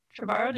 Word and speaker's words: Trevorrow: Trevorrow, did I say that right? – Yes, they actually just snagged Colin Trevorrow for their film, which is pretty exciting.